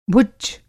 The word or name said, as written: भुच्च